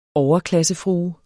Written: overklassefrue

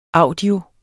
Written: audio

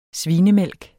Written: svinemælk

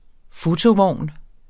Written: fotovogn

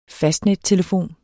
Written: fastnettelefon

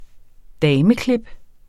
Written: dameklip

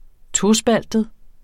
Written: tospaltet

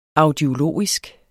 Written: audiologisk

